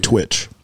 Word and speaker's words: tWitch: Yeah, DJ Twist. – DJ Twitch.